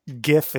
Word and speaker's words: GIF: Just someone's wrong, and it's GIF. – The people who say GIF are wrong. – Is wrong. Yes, exactly. – Yes. – Yes. – That's a hard G.